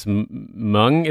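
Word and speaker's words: Mwng: I think it's Mung, is how you pronounce the name of the album? It's M-W-N-G that was, they put out in 2000 that was all in Welsh.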